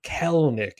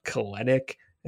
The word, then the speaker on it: Kelenic: Uh, important Kelnick. It's not Kelenic. It's not Kalenic. It is Kelnick. Two syllables.